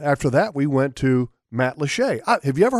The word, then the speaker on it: Matlacha: If you were to look at it on a map, it's spelled Matt Lacha. – Okay. – M-A-T-L-A-C-H-A.